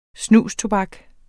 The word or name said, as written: snustobak